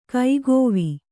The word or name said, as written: ಕಾಕಿವೆ